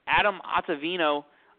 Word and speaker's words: Ottavino: All right, so our next person here on the segment is Adam Ottavino. Did I butcher that last name? – You did not.